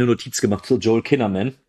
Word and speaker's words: Kinnaman: Du hast den Joel Kinnaman, wenn ich ihn jetzt richtig ausspreche. – Kinnaman, genau heißt er.